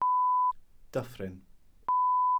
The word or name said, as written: dyffryn